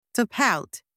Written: to pout

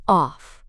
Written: off

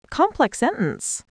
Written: complex sentence

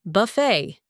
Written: buffet

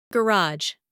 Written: garage

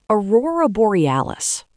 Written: aurora borealis

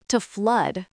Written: to flood

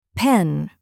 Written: pen

